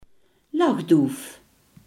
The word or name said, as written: tortelduif